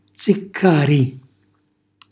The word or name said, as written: ziccàri